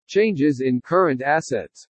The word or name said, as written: CHANGES IN CURRENT ASSETS